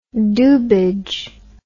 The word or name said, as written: doobage